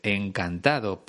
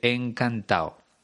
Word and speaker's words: encantado: Como has podido escuchar, yo he pronunciado la D en el participio, encantado, pero Roberto Berino dice encantado. Es algo que hacemos habitualmente en España, sobre todo en el lenguaje informal, pero te recomiendo que no lo hagas.